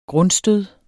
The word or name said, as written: grundstød